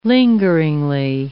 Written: lingeringly

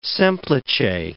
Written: semplice

